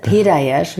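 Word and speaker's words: Piedayesh: Und ich heiße Peter Hirsch, wie Peter Hirsch. Ganz zart ausgesprochen. Also Leila Peter Hirsch.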